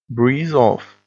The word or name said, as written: breeze off